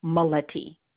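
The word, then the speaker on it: Mileti: It's Maureen, M-A-U-R-E-E-N. Last name is, and I'll spell it, M as in Michael, I-L-E-T-I, Maletti.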